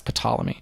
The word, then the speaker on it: Ptolemy: I'm sure it's probably the P is silent. – Mm-hmm. – No, it's Ptolemy.